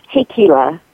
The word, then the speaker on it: Hikila: It's Hikila, and I know it's a mouthful.